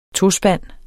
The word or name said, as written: tospand